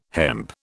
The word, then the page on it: hemp: British English